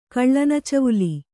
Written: ಕಳ್ಳನಚವುಲಿ

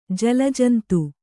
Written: ಜಲಜಂತು

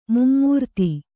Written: ಮೈಮರೆತ

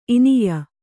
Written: ಇನಿಯ

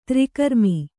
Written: ತ್ರಿಕರ್ಮಿ

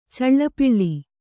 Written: ಚಲ್ಲಪಿಲ್ಲಿ

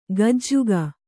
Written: ಗಜುಗ

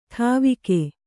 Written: ಡವಿಗೆ

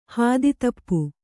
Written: ಹಾದಿತಪ್ಪು

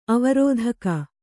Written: ಆವರ್ತಕ

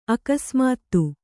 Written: ಅಕಸ್ಮಾತ್ತು

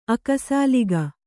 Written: ಅಕ್ಕಸಾಲಿಗ

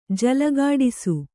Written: ಜಲಗಾಡಿಸು

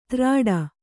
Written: ತ್ರಾಡ

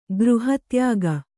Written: ಗೃಹತ್ಯಾಗ

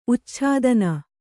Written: ಉಚ್ಛೇದನ

